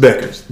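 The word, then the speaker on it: Bueckers: It's just Beckers. The U is silent.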